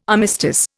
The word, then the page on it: armistice: Indian